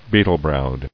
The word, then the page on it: Beetle-browed: bee·tle-browed